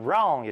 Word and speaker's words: wrong: S-H-O-U-L-D. 자, 마지막 단어 wrong. 잘못된, 틀린 이라는 뜻입니다. 자, 이때 W 발음 안 하고 그냥 wrong 이라고 한다는 것. 그래서 한번 적어볼까요? W-R-O-N-G.